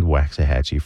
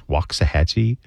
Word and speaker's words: Waxahachie: The band Waxahachie from Tennessee. That's what they call it there, so don't get on to me about that pronunciation. But here in North Texas, it's Waxahachie.